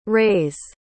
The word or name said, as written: ray's